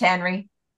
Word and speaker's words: Henri: His name is spelled H-E-N-R-I, like it should be Henri, you know? And it's just Henry.